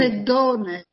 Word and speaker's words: Sedone: Scusa, com'è il cognome esatto di Anna? Come si pronuncia? – Sedone. – Sedone. Sedone, ah. – Sedone. – Con l'accento qua, ok.